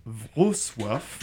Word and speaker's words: Wrocław: Comment on prononce Wrocław là C'est Wro... Wrocław. Wrocław. C'est dans euh, l'ouest de la Pologne.